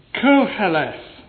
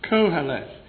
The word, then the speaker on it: Qoheleth: In the Hebrew, it's Koheleth, spelt with a Q in English letters and without a U, I noticed in my Bible. Q-O-H-E-L-E-T-H. Koheleth.